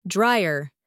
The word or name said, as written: dryer